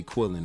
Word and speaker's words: Quillan: And you can find me on Facebook at Quillan Mathis. You say chilling, then you say Quillan. That's how you pronounce my name.